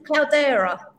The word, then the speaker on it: Cloudera: Is it Cloudera? Have I pronounced that right, David? – Yep, that's right. It's kind of a combination of cloud and era.